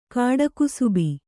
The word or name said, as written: ಕಾಡುಕುಸುಬಿ